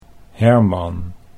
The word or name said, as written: Herman